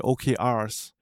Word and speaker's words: OKR: Ne, ausgesprochen. Nee, nee, nicht. Äh, es ist O-K-R.